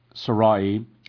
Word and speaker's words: Sarai: Now, Sarai, and this has been pronounced in different ways, Sarai, but it's Sarai, Abraham's wife, bare him no children.